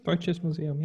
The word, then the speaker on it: Deutsches Museum: But where is the computer is actually am I pronouncing this right? The Deutsche Museum in Munich. – Mm-hmm. Deutsches Museum, yeah.